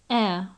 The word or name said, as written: air